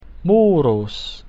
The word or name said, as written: murus